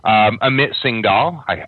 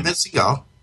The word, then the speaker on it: Amit Singhal: For people who don't know, Amit Singhal, I'm probably mispronouncing that name. – No, that's not right. Amit Singhal.